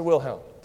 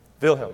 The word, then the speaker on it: Wilhelm: I say it in the German way. Kaiser Wilhelm. Did I say it right? Any German speakers here? Yeah. Wilhelm.